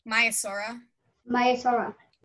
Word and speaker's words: Maiasaura: So how do you pronounce its scientific name? – Maiasaura. – Yes. Another pretty easy one. Everybody pronounces this one the same, so it's not too hard to pronounce.